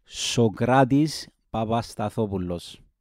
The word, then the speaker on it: Sokratis Papastathopoulos: Let's talk about Sokratis Papastathopoulos. Yes, that is how you pronounce it. Sokratis Papastathopoulos.